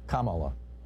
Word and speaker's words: Kamala: Kamala, I've heard. – They mispronounce it on Fox News, and Trump mispronounces it as a way of slurring her. It's Kamala.